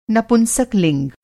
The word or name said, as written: नपुंसक-लिंग